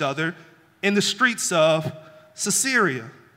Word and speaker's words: Caesarea: I'm hoping I'm saying that right. But it's almost like Caesarea. Caesarea, there it is.